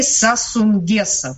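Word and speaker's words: Sassou Nguesso: Потому что как раз выборы в эти выходные, там завершились очередные президентские выборы, и победил действовавший на тот момент Денни Сассу Нгесса. Правильно ли я произношу? – Абсолютно правильно и точно.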